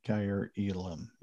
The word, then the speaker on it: Kaiir Elam: Kaiir Elam? Yeah. – Yeah, Kaiir Elam.